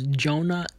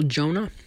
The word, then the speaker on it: Jonah: My username is Jonah N, and the way you spell Jonah is J-O-N-A-H. The way you spell it is J-O-N-A-H.